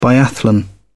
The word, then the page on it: biathlon: British English